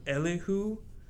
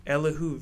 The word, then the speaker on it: Elihu: I think his name, I think it's pronounced Elihu, but he's an American painter. It's like E-L-I-H-U Vedder, Elihu Vedder.